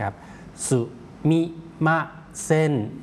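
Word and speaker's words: สุ มิ ม า เซ น (すみません): ตัวเซกับตัวอึนะครับหรือตัวอึในภาษาอังกฤษเนี่ยนะครับคนญี่ปุ่นออกเสียงรวบยาวไปเลยออกเสียงว่าเซนครับออกเสียงรวบแล้วลากยาวนะครับเซนครับสุมิมาเซน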